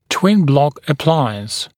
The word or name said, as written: twin block appliance